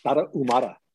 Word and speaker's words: Tarahumara: Tarahumara.